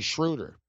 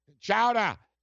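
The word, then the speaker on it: Schröder: But it's actually Schroeder. It's not Schroeder. It's Schroeder. Schroeder, say it. Chowda, it's Schroeder. He's German. He's German. Dennis Schroeder. Yeah, he's a German dude. So Dennis Schroeder left the bubble.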